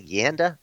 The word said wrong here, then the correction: Yanda: No, it's spelled that way, but it's Yonda, I mean.